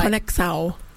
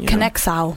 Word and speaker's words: Conexão: Conexão. – See, I knew I fucking You got to get all, like, you know, Brazilian Portuguese with it. – Conexão.